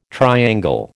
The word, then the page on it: triangle: American English